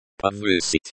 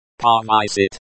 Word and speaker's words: Повысить: Повысить. – Повысить, to raise, to increase. This is the infinitive form of the verb. The stress is on the second syllable.